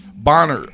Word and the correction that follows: Boehner: Is it Boehner? How you say his name? – John Boehner.